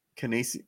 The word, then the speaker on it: Canisius: Canisius? Is that how you say it? – Canisius.